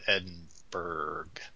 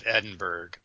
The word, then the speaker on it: Edinburgh: Nobud Opsis by Prince Philip, Duke of Edinburgh. I don't see why I wasn't king. What the hoodwinks was up with that? My name is Prince Philip, Duke of Edinburgh, although we pronounce it Burrow.